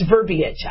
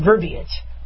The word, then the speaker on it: verbiage: It's not verbiage, it's verbiage. I saw that because I had a spell check. Yeah, there's an I in there. So it's pronounced verbiage. You guys might say verbiage, but I have to remember there's an I in there, otherwise I misspell it.